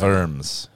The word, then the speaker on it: Worms: Worms. – Worms, yeah. – Is actually how you say it.